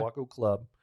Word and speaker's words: Waco: Waco Club. – Yeah. Am I saying that right? – Yeah. – Waco Club.